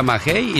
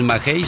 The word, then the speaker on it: maguey: Me dice un señor que el quiote viene del maguey, se pronuncia magey, y del maguey se hace el mezcal. O yo digo que es maguey porque en los magueyes están verdes.